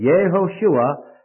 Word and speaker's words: Yehoshua: What you're looking at here, Jehoshua, or Yehoshua, as the Jew would pronounce it, Yehoshua, this is the long form of the name.